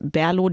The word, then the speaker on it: BRLO: Genau, von Berlo habe ich ein schönes helles mitgebracht. Eine Berliner Craft-Bier-Brauerei. Geschrieben auf der Flasche steht aber tatsächlich nur BRLO.